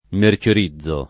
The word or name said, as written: mercerizzare